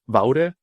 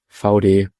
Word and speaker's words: Vaude: Also ich habe laufend Vaude gesagt. Bin dreimal ausgebessert worden. Ich habe den Job trotzdem bekommen. Aber das wäre jetzt so alle Anekdoten rund um unsere Aussprache. Also, liebe Leute, VD, so. Okay. V und B halt mit den Füllbuchstaben dazwischen.